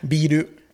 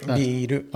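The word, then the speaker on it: Biru (ビール): Biru. – Is it? – Biru.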